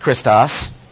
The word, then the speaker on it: Christos: Pronounced a little bit different, Christos.